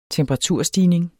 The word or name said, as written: temperaturstigning